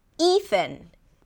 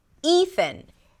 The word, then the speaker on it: Ethan: This name has two syllables. The first syllable is E, E, like the beginning of the word eat. And then it's a TH sound and an N, thin, thin. Just like Jonathan, thin, Ethan, Ethan.